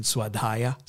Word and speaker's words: swadhyaya: And swadhyaya, self-study. Swadhyaya, that's a correct pronunciation.